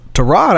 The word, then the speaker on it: Tyrod: It's Tyrod. Everybody's been saying his name wrong. It's not Tyrod, ladies and gentlemen. It's Tyrod.